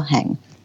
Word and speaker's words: Heng: Rachel Heng.